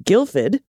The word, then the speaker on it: Guildford: Just up the road is the town of Guildford, which is pronounced Guildford.